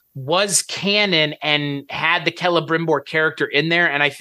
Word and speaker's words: Celebrimbor: The only thing that I never realized about this character is that it is a hard C rather than a soft C, because I used to always call him Celebrimbor when I was reading it. – Yeah, Celebrimbor is...